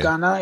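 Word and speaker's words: Gaana: Yeah, the feed that I'm talking about, is, I don't know how to pronounce it, but it's G-A-A-N-A. But apparently that's like a big... Yeah, G as in Ghana. – Girl. Yeah. – But apparently Ghana. Like, so a, Ghana means song.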